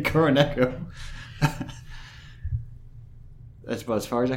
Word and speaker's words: Kuroneko: Or Kuroneko. – Kuroneko? – As it's pronounced in Japanese.